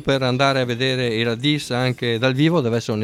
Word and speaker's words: Radìs: Ricordiamo anche appunto che oltre al sito, volendo, ci trovate anche su Facebook, basta scrivere Radis con l'accento sulla is.